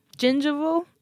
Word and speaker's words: gingival: gingival bleeding? Gingival? – Gingival.